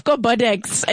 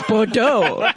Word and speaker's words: Bordeaux: And then they're like, I'm getting off Bodex, and it's Bordeaux. But it's spelled, Bordeaux. I mean, it's spelled with an X, and you're like, why are you messing with people?